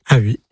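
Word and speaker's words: Haüy: Donc Valentin Aoui, ça s'écrit H-A-U-Y, mais ça se dit Aoui. – Aoui.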